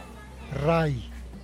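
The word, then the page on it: rai: Teotitlán del Valle Zapotec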